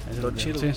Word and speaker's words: Don Cheadle: Ben Kingsley y Don Cheadle, creo que lo pronuncié mal, pero... – No, no sí, Don Cheadle, sí.